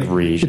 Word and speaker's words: Riege: R-I-E-G-E. It's like Rige.